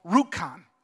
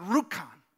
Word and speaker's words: Rjukan: There's this little town in Norway called Rjukan. Now, I'm butchering the name because I can't roll my R's the way they would do that in Norway. But Rjukan.